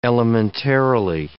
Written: elementarily

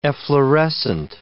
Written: efflorescent

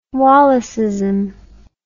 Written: wallaceism

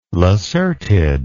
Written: lacertid

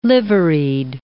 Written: liveried

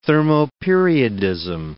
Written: thermoperiodism

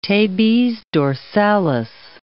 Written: tabes dorsalis

